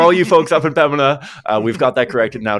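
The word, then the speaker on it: Pembina: Be careful, don't pronounce the B in Pemina. I've been told that on several occasions, Pemina.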